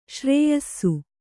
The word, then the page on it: ಶ್ರೇಯಸ್ಸು: śrēyassu